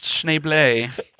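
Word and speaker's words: Schneebly: Oh, actually, it's pronounced Schneeblay.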